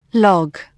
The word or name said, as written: log